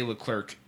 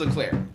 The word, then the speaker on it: Leclerc: They say LeClerc every time he comes on the mound. And Chad also says LeClerc. I think maybe... So, okay, where's he from? – The guy in F1. – No, it's pronounced LeClaire.